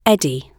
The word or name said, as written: eddy